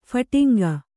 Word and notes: ಫಟಿಂಗಿ: phaṭingi

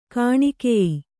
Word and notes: ಗೋನಿಕಾಯ: gōnikāya

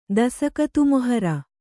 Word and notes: ದಸಕತ್ತುಮೊಹರು: Kannada; dasakattu moharu

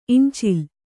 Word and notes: ಈಂಚಿಲ್: īñcil

